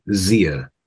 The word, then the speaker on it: Tzia: I'm just gonna go with Zia. Like the Zia. T is silent. – Yeah, I have no idea. – T is silent. Yeah, like pterodactyl.